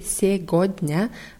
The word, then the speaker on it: сегодня: Das Wort wird mit G in der Mitte geschrieben. Сегодня mit EG, сегодня, ausgesprochen aber сегодня.